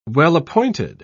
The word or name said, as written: well-appointed